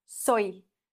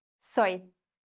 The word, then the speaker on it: soy: Vielleicht noch das Y wird wie ein I ausgesprochen. Soy. Soy. Vale?